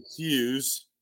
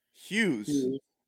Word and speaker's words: Hughes: It's Huge. Hughes. Yeah, not Huge.